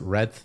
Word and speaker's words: Redth: Uh, you might know me as Redth on the internet, R E D T H, all one syllable. Finally, the truth. It's not two syllables.